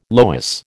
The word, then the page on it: loess: American English